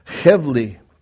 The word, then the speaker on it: hevli: It almost looks like Chevy there, but it's hevli.